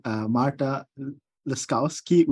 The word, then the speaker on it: Marta Laskowski: And we're very fortunate to have Marta Laskowski with us.